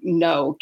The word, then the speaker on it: Knowe: I pronounce it no, K N O W E, um, all of those books Miss Whitehead um, gave to me.